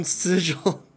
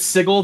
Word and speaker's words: Sigil: Hey, El Felidel is from Sigil. – And we've already started hopping demiplanes. – If anyone pronounces it Sigil, they're wrong. – That's how the creators of that setting pronounced it.